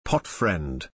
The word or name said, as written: potfriend